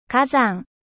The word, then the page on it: volcan: French